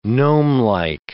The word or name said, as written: gnomelike